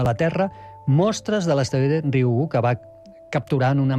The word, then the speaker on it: Ryugu: Ryugu. Ryugu. És molt fàcil, com una I, Ryugu.